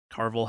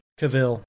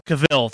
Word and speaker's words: Cavill: Jen, my wife, is not such a big fan of Henry Cavill or Carvel, how the fuck you pronounce his name? – Cavill. – Cavill, thank you, for being Geralt, and I get it, but also he's playing Geralt really well.